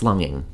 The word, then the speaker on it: longing: What about the hard G in like longing? I hate that. It's longing, right? – Like longing.